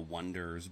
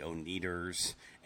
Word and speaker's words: Oneders: It is the story of a one-hit wonder band from the middle of nowhere They're called the Wonders, but for a long time they spell it O-N-E-D-E-R-S, and everybody thinks it's the O-Needers, and they have this song called That Thing You Do, and it blows up.